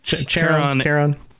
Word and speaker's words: Charon: And of course, Charon, I think I'm pronouncing that correctly. – Charon. Yeah. – Charon, Karen, hopefully not Karen.